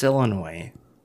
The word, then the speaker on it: Illinois: No, it's Illinois. – No. Oh, you can say it both ways. – But you'll sound like dumb if you pronounce the S.